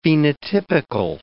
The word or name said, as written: phenotypical